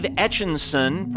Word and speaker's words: Etchinson: Christopher Towler, Dave Etchinson, parentheses, sounds like Etch-a-Sketch, I got it, Dave, Ray Hawley III, J.R.